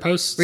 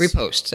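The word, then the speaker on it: riposte: You know, there's powerful moves, there's backstabs, and what do you say, reposits? How do you say that word? – Reposts. – Reposts, thank you.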